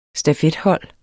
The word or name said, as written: stafethold